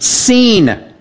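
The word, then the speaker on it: seen (סין): This Hebrew word sin is actually a Hebrew word, and they pronounce it seen. And again, no derivation. There's no origin of this word. It's not the same word as sin, as in doing something wrong.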